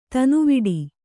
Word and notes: ತನುವಿಡಿ: tanuviḍi

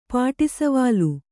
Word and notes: ಪಾಟಿಸವಾಲು: pāṭi savālu

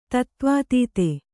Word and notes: ತತ್ತ್ವಾತೀತ: tattvātīta

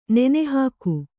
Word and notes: ನೆನೆಹಾಕು: Kannada

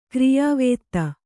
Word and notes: ಕ್ರಿಯಾವೇತ್ತ: kriyāvētta

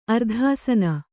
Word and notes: ಅರ್ಧಾಸನ: ardhāsana

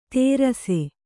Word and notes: ತೇರಸೆ: Kannada

